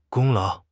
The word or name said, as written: 功劳